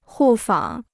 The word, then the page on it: 互访: hù fǎng